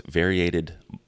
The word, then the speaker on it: variegated: I think, so those whales, those smaller whales, the minke whales that we were chasing last year, they have what's called, variated... Oh, I'm going to butcher it. They have this huge blubber pouch on the bottom of their throat with this variegated blubber muscle.